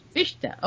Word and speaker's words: Fichte: Fichte, okay.